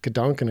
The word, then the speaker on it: Gedanken: Well, you said it pretty accurately. Gedanken experiment is, I mean, how I would say it. I think the general rule is when you take a foreign word, you pronounce it like you would in English. But I think that is the German pronunciation.